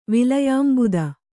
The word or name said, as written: ವಿಲಯಾಂಬುದ